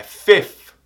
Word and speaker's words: fifth: O quinto não é five, é fifth. Fifth. E aí muitas pessoas sofrem para pronunciar isso porque você tem que fazer um som de f, fe, f, para no f, que quem faz esse som é o seu lábio de baixo, f, f, e depois você abaixa esse lábio e coloca a língua entre os dentes e assopra.